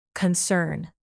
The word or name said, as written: concern